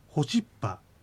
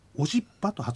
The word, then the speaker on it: オシッパ: さらにその次なんですけども、テキストをお持ちの方はローマ字ではホシッパとエッチの音が書かれていましたが、私は今あのオシッパと発音しました。あるいはローマ字の下にあるカタカナの部分というのはオシッパと書かれています。これはあのどういうことかというと、石狩川筋のアイヌ語ではホシッパという発音もオシッパという発音も両方ともあるわけです。